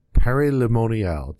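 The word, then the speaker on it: Paray-le-Monial: go to Père Lemonial. It took me about, 4 months to figure out how to pronounce that? – Not quite that long, but well done. Well done indeed.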